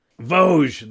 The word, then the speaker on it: Vosges: Vosges. The Vosges Mountains. – Oh, that's so cool. – Okay, so it's the Vosges Mountains, not Vosges.